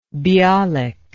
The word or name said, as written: Bialik